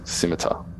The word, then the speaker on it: scimitar: Side note, I believe the C in the scimitar is silent. – Oh, yes, scimitar.